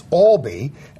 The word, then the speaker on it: Albee: Well, when I saw The Inheritance, one of the characters goes, Edward Albee. And, of course, it's Albee.